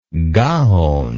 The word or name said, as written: gaon